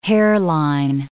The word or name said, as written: hairline